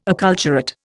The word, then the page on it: acculturate: Indian